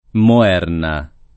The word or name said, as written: Moerna